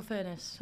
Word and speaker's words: Furness: How do we say your name? – Yeah, Rachel Furness. – Furness. – Yeah. I think people used to say finesse, and I was like, oh, it's a bit posh.